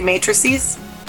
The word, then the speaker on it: matrices: One Matrix, three Matrices? – Sure. Okay. – Is that how you say it? – If you want to be technically correct, then yeah.